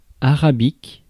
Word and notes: arabique: [a.ʁa.bik]; France

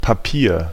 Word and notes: Papier: /paˈpiːɐ̯/; /paˈpiɐ̯/

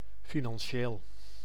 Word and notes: financier: /fi.nɑ̃.sje/

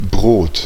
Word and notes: bread: /bɹɛd/

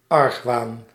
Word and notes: méfiance: /me.fjɑ̃s/; France

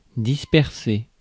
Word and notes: spread: American English; /spɹɛd/

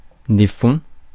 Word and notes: field: [fild]; American English

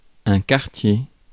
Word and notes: neighborhood: [ˈneɪ.bɚ.ˌhʊd]; /ˈneɪbəhʊd/; /ˈneɪbəɹhʊd/

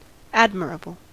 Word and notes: admirable: American English; /ˈæd.mɚ.ʌˌbl/

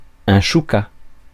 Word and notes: choucas: [ʃu.ka]

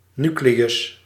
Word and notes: ядро: /jɪˈdro/

